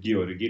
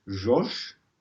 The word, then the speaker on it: György: Det skrives Georgi Ligeti, men ifølge våre kilder så uttales det George.